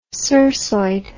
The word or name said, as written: cirsoid